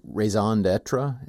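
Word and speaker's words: raison d'être: raison d'etre.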